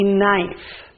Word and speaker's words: knife: Use a knife. Let's write that down. So let's write the word knife first. Knife. And we said that in knife, the letter K is silent, so we are going to underline the letter K, and we're going to write our sentence.